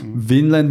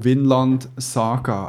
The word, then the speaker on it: Vinland Saga: Wie schreibt man es? Winland mit V, Winland Saga.